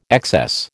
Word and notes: excess: American English

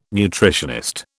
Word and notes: nutritionist: British English

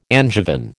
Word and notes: Angevin: American English

